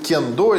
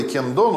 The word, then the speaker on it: кендо: ну можно говорить кендо или кендо, ну по-японски кен меч, до путь.